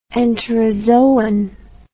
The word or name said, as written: enterozoan